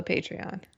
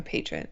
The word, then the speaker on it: patron: So I'm still a Patreon. A patron. I like saying a Patreon. – Yes, yes. I know you like saying it wrong, but yeah, you should probably specify that for the people at home.